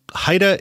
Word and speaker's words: HIDTA: HIDTA is how we pronounce the acronym that stands for High Intensity Drug Trafficking Area.